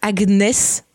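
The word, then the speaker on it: Agnes: Donc, la voisine s'appelle Agnès, qui en anglais se prononce Agnès, donc comme tu vois Agatha Harkness.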